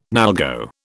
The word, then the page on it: NALGO: British English